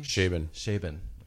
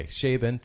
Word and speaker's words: Chabon: But Michael Chabon, how do you say that name? – Chabon. – Okay, there we go. I've heard him speak. I've read his books. Okay, Chabon, thank you.